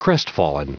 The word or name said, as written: crestfallen